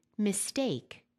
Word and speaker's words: mistake: Stressing the wrong syllable in a word is a frequent error. For instance, it should be mistake, democracy.